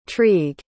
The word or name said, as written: trygg